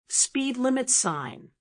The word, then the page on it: speed limit sign: American English